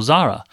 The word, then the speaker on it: Zahra: And in fact, the closer pronunciation to where my, you know, her Indian and parents and grandparents would be more Zahra. Okay. So the hard H is for the sort of slightly pretentious Arabized Muslim who thinks things are only authentic if they're actually Arab.